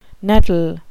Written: nettle

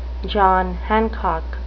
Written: John Hancock

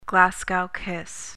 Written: Glasgow kiss

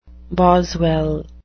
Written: boswell

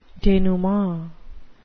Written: denouement

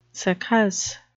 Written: succus

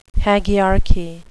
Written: hagiarchy

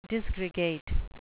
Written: disgregate

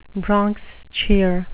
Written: bronx cheer

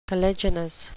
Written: caliginous

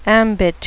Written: ambit

